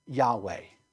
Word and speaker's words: Yahweh: We pronounce that name, Matt pronounced it this morning, as Yahweh, right? So when you see that word Lord, L-O-R-D, all capitals, you can think in your mind, that is the name of God. That is Yahweh, right?